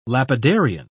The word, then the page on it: lapidarian: British English